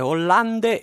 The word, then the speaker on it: Hollande: si scrive Hollande e si legge Holland, per favore, siamo stufi di tanta approssimazione.